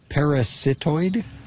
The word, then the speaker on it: parasitoid: Parasitoid? – Yes, parasitoid wasps.